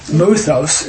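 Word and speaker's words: muthos (μῦθος): and that word is really muthos, that's how it's pronounced, it's a Greek word.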